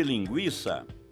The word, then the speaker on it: linguiça: No caso de linguiça, trata-se de ditongo, o I, pronunciado de uma só vez. E por isso a letra I não leva acento.